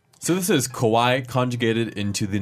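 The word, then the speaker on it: かわいい: Not to be confused with kawaii. – Ah, it's the same. Their pronunciation is really close. – Really close. – Kawaii, kawaii. – Very close, very close. So we have kawaii, which is cute. And then we have kowai.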